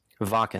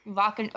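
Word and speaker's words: Wacken: Right now I have stuff up for the, was it pronounced Vakken? – Vakken. – The Vakken Open Air Festival in Germany.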